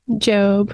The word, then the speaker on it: Job: was it Job or Job? – Job. – Mm-hmm. Job. Spelled like Job, but Job, right? – Mm-hmm.